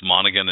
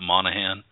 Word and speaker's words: Monaghan: Have we all come to the conclusion that it's Monaghan and not Monahan? Because somebody was saying that we need to pronounce it Monaghan.